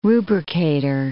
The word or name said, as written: rubricator